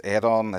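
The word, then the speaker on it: Aodhán: And because obviously it's Aidan, it's spelt A-O-D-H-F-A-D-N, that obviously I'm anti-British, I'm a rampant Republican.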